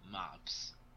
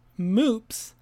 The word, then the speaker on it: Mops: All right, so no point there, but mops, or as it can also be pronounced, moops, is the German/Russian word for pug.